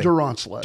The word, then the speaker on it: Duronslet: Did I say your last name right? – That was close enough. – Say it correctly. – Duronslet. – Duronslet. – Yes, sir. – There you go.